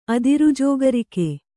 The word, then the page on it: ಅದಿರುಜೋಗರಿಕೆ: adirujōgarike